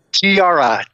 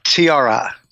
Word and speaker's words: Thiara: Tiara, Tiara. Oh. Tiara, tiara, actually, officially. – All right, like a crown.